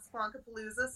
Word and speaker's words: Squonkapalooza: Yeah, originally it was going to be Squonkfest, but we thought Squonkapalooza seemed a little more festive.